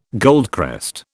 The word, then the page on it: goldcrest: British English